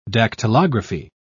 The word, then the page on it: dactylography: English; British English